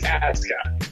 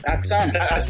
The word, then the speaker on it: Asuka: Asuka? Is that how we're saying it? – WWE is doing one thing right because Japanese pronunciation, you never pronounce the U. Okay, but it's Asuka. – Asuka.